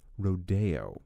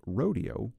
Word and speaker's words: Rodeo: This ballet, for reasons I don't fully understand, has been sometimes pronounced Rodeo. I don't know if it's because it sounds more pretentious or who knows what, but the conductor that night, who was a British conductor, said, as far as he knew, Copland was on the record saying it was supposed to be pronounced Rodeo, like you would expect when you read it.